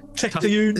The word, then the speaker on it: Tatooine: Tatooine. Is it that difficult for everybody? – It is three syllables.